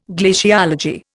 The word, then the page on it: glaciology: Indian